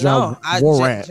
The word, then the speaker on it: Ja Morant: Can you say his name correctly? It's Ja Morant. It's Ja Morant.